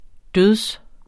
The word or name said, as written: død